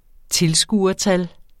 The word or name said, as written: tilskuertal